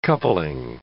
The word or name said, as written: coupling